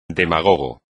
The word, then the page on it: demagogo: espagnol